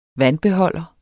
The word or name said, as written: VANDBEHOLDER